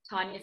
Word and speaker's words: Tanya: So just uh, to get in touch with me, uh, you can find me on LinkedIn, uh, Tanya Savas,